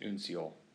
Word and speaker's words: uncial: You can see there in parentheses, I think it would be pronounced uncial.